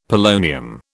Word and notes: polonium: British English

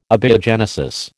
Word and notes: abiogenesis: American English

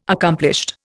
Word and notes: accomplished: Indian

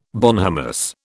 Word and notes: bonhomous: British English

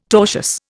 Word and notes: tortious: Indian

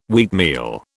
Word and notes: wheatmeal: British English

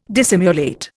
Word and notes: dissimulate: Indian

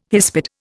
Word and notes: hispid: Indian

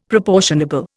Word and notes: proportionable: Indian

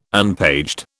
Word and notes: unpaged: British English

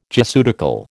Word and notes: Jesuitical: American English

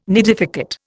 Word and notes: nidificate: Indian

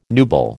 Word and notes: nubile: American English

